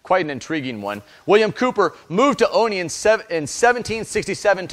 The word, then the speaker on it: Cowper: One of the greatest masters of the English language in the 18th century was the mentally troubled and theologically deep William Cooper, which is spelled Cowper, just so you know.